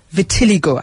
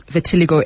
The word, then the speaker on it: vitiligo: Nash wants to know what kills off melanin cells in the human body that causes vitiligo, and how can it be stopped? – Yes, the pronunciation is slightly different. It's vitiligo, mm-hmm.